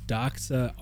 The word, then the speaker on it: Doxa: It rhymes with Poxa. – Oh, interesting. Yes. Tell everyone what you bought. ignore my Snickers. Go on with the show. – So this is the newly released Doxa Army.